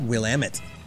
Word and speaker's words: Willamette: Oh, how do you pronounce that? – Willamette. – Like Will I Am.